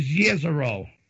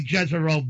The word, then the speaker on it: Jezero: I know people are saying that the real way to pronounce that is Jezero, but, you know, I like Jezero better.